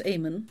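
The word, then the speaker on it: Eamonn: Well, you know, Eamon's name is an interesting one because it's pronounced Eamon, and depending on the spelling of it, it can either be recognised as an Irish name, and his goes with that spelling, spelled with an E, or it can be a recognisably Muslim name, Aymann.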